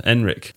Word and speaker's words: Ehrenreich: Yeah, Ehrenreich.